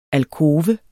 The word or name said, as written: alkove